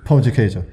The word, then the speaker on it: pão de queijo: Pão de queijo, yeah.